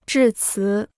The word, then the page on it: 致辞: zhì cí